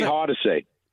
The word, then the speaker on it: Hardesty: Larry Hardesty. – You're giving it. – Is it like Hardaway with the Hardesty? – Nope.